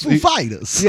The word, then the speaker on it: Foo Fighters: "Where's the is the emphasis on the Foo or the Fighters?" And they were both just like, "The Fighters." So that's Foo Fighters.